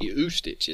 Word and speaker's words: Ustich: U-S-T-I-C-H. Ustich is how I would say it. – I'm not sure. – And it's Anna. – Well... – Okay. Her name is Anna... Ustich. – Yeah, I believe that's how you say it.